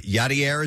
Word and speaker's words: Yadier: Yadier, is that how you say his name, Nick? – Yeah.